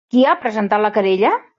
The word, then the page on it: presentat: catala